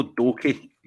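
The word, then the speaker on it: Doekhi: Was it Doeker? Did you say his name? – No, Danilo Doke.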